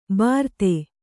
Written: ಬರ್ದೆ

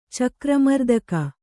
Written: ಚಕ್ರಮರ್ದಕ

